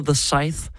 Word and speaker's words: The Scythe: Ej, hvordan siger man det? Jeg er kommet fint udenom aldrig at skulle udtale det her navn i radioen før. – Nu er jeg faktisk blevet en lille smule tvivl, fordi at du putter mig on the spot. Ja. Den hedder The Scythe.